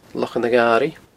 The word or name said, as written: Lochan a' Gharaidh